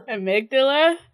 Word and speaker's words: amygdala: And so the Ugmandal. I don't know how to say it. It's a part of the brain, a part of the limbic system. – The amygdala? – Sure. The amygdala.